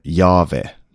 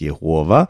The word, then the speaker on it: Jahve: Och det uttalar man Jahve. Tidigare så trodde man att det skulle uttalas Jehova. Men det är antagligen en missförståelse.